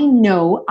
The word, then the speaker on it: know: E aí eu fiz questão de relembrar para você que essa palavra know, do verbo saber ou conhecer, tem exatamente a mesma pronúncia do know, do não. Certo? É esse O fechadinho, né? Oh, no. E o K é mudo.